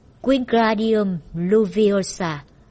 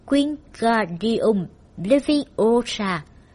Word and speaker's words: Wingardium Leviosa: Nó vung vẩy cánh tay như cánh quạt gió, đọc to: Wingardium Leviosa. Mọi người đều nghe Sabrina cào nhau. Bạn đọc sai rồi, phải đọc là Wingardium Leviosa. Kéo dài chữ ga một cách duyên dáng.